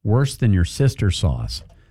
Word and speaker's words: Worcestershire: Bean says Worcestershire sauce is actually pronounced worse than your sister sauce.